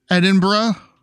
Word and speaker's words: Edinburgh: Our first news story comes to us today from across the pond over in Edinburgh.